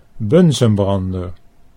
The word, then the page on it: bunsenbrander: Dutch